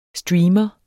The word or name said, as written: streamer